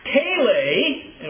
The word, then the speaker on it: Peleg: Now, Peleg, and once again, there are long pronunciations on both A's there.